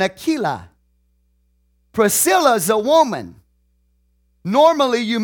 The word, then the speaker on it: Aquila: Priscilla and Aquila, or Aquila, some people will pronounce it. It's Priscilla and Aquila.